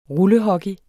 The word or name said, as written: rullehockey